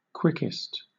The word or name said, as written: quickest